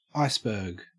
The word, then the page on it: iceberg: Australian English